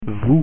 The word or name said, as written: vous